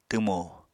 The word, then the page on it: twmo: apatani